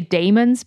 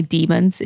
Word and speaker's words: daemons: I say daemons, but apparently demons is the correct way to say it, but it's spelled D-A-E.